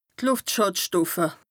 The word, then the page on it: d' Lùftschàdstoffe: Bas Rhin